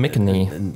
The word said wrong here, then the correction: McInnerney: Tim McKinney hat McInnerney? – Ja, ich hab's mir von Google aussprechen lassen, tatsächlich einmal. – Okay. – Tim McKinney, Moment. McInnerney. Tim McInnerney hat sich entschlossen.